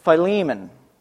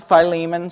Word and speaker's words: Philemon: So, restoring relationships, keeping koinonia, and no better place to look at this than in the book of Philemon, or Philemon, however you want to pronounce it. I pronounce it Philemon, so that's how you're going to hear me say it from here on out.